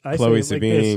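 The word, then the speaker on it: Chloë Sevigny: Chloe Sevigny.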